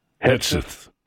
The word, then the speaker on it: Hegseth: Hegseth.